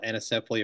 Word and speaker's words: anencephaly: Our first child died when we were in Australia of a condition called anencephaly, or some pronounce it anencephaly.